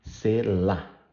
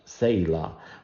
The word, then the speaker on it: Selah: And by the way, some people were asking me, in Portuguese, it is pronounced sela, sela, the stress in the last syllable, okay? In English also, but I hear people putting a little A in the first syllable, so they say sela.